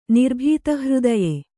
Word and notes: ನಿರ್ಭೀತಹೃದಯೆ: Kannada

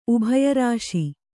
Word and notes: ಉಭಯರಾಶಿ: ubhaya řāśi

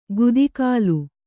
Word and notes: ಗುದಿಗಾಲು: gudigālu

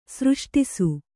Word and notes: ಸೃಷ್ಟಿಸು: sřṣṭisu

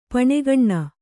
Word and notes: ಪಣೆಗಣ್ಣ: paṇegaṇṇa

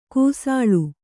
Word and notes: ಕಾಸುಳಿ: Kannada

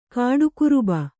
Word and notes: ಕಡುಗೊರ್ಬು: kaḍugorbu

